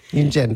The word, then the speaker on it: Yunjin: Yunjin.